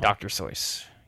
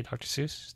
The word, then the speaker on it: Seuss: Dr. Soyce. You know that's how you say Dr. Seuss? Dr. Seuss? – Is it? – That's how it was originally pronounced. That's how it's supposed to be pronounced, but people got it wrong, so then I think they just rolled with it.